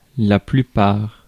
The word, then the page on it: plupart: France; /ply.paʁ/